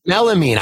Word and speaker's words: melamine: It's called like Miramine. I never say it right. My mom gets mad at me every time. But then Melamine.